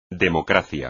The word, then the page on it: democracia: espagnol